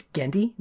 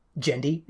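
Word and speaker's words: Genndy: Gendy Kartakovsky, who, by the way, it's Gendy, not Gendy. It's a hard G.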